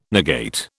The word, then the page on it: negate: British English